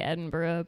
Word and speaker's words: Edinburgh: How the fuck do you actually pronounce that? Does anybody know? – The locals say Edinburgh, but you know, I'm American.